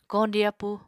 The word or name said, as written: koñdi appú